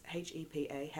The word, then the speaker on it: HEPA: We have a, a I think it's a I don't know how to say this. H E P A HEPA HEPA HEPA filter that's like the filter for dust, um, and it's it's worked a charm.